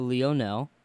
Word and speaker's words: Leonel: How did my computer pronounce this? It doesn't even want to attempt it. L-E-O-N-E-L. So Lionel.